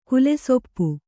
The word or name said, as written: ಕುಲೆಸೊಪ್ಪು